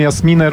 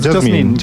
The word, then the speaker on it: Jasmine: To jeszcze cię zapytam o wymowę imienia Paolini. Jasmine, prawda? – Bo Jasmine, bo ona sama o sobie mówi. – Ja słyszałem Jasminę, różne wersje. – Nie, Jasmine, bardzo dobrze.